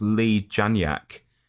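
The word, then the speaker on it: Leigh Janiak: One thing I've got to say at first, though, is the director, I think it's pronounced Lee Janiak.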